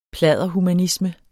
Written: pladderhumanisme

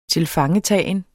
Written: tilfangetagen

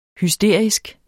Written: hysterisk

